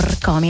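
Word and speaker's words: Rkomi: E adesso cambiamo decisamente genere, andiamo sulla musica italiana, parliamo di Rcomi, un artista, poi lo pronuncio in modo esatto perché ci tengo, Rcomi, in realtà lui si chiama Mirko, ha fatto una sorta di anagramma appunto del suo nome, come ha già spiegato in passato.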